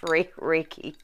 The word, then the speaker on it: Reiki: But I know, so, if anything, I know that it's pronounced Reiki. – Reiki? – Reiki, yeah. – Not Reiki. – Like, okay. No. – Reiki. Okay, perfect. Thank you for that, because I would have been saying that wrong the whole time.